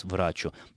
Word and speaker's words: врачу: что ударение не смещается на окончание, поэтому никаких оснований для того, чтобы произносить это слово с иным ударением, кроме, как подчеркну еще раз, «врачу», не существует.